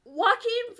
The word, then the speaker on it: Joaquin: And Jaquan Phoenix in, like, water. – Joaquin Phoenix. Apparently, they wore tinfoil hats. – Is that how you pronounce it? – Yeah.